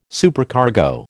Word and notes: Supercargo: American English

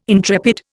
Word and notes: intrepid: Indian

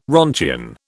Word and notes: roentgen: British English

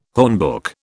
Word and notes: hornbook: British English

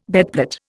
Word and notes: bedplate: Indian

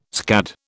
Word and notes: scad: British English

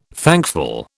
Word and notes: thankful: British English

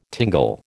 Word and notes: tingle: American English

